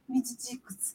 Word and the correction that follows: midiáticos: Como é que fala? Midiáticos. – Midiáticos.